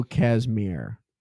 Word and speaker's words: Kazmaier: So the guy that I'm talking about is Bill Casimir. – Okay. – It's spelled Kazmaier.